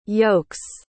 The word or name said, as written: yokes